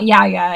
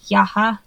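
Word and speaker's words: Yahya: His first name is Yaha, I think it's, or Yaha. It's Y-A-H-Y-A, but I think you have to put like a stress on the H. It's not Yaya anyway. I think it's like Yaha.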